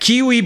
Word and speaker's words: Qiui: There is a company called Kiwi. It's spelled Q-I-U-I.